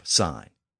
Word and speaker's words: sign: That same text also contains the first use of the word sign, as in a stop sign. And sign is another word that has a silent letter in it as a Latin marker. Of course, it's the letter G. We don't really need that G in there.